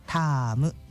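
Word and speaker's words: 田芋: タームというのはタロイモというか田んぼの芋って書くんですけど、タームと読むんだそうですよ。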